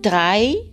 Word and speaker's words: drei: Por ejemplo, en las palabras drei y mein, donde aparece la EI en ambas palabras, esa EI no se pronuncia drei ni mein, sino ai drei y mein. O sea que la unión de la E y la I siempre se pronunciará i, como en drei, tres, y mein, mío.